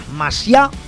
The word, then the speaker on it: Masiá: El hermano de este, Javier Masiá, con acento en la última A, al bajo, y la batería de Iván Tachella.